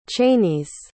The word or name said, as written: cheyneys